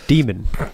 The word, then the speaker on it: Daemon: Damon. I don't know. – Demon. D-A-E-M-O-N. – Is it pronounced demon.